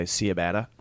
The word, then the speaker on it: ciabatta: Ciabatta. – Ciabatta.